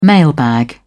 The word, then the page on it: mailbag: British English